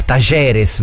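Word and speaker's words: Talleres: No, poi ringrazio anche Filippo perché ci ha detto la pronuncia corretta, Tajeres, perché la doppia L.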